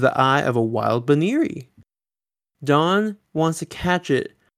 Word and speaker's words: Buneary: Now, this episode does kind of establish the correct pronunciation which is more like Buneary, but I might just switch to the other one as I go through because it's a little easier to say.